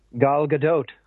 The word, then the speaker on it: Gal Gadot: So it is actually Gal, as in ball, I guess, and Gadot with the pronouncement of the T at the end. – Gal Gadot.